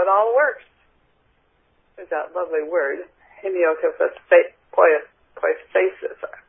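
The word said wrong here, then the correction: hemopoiesis: Let me go back. I put that back there so I'd remember how to say it. I wish I could put the sound of it on here. Hemiopoiesis.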